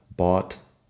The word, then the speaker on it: bought: Fíjate que suena igual aparte de la primera letra ahí. Lo que pasa es que caught se escribe con C-A-U-G-H-T mientras que bought se escribe con B-O-U-G-H-T. En todo caso, la pronunciación de la vocal es igual en las dos.